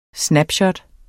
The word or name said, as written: snapshot